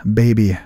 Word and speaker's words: BBY: That is the band, Baby. Spell it B-B-Y.